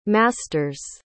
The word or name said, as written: masters